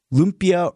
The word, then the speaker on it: lumpia: Lumpias are really, really good.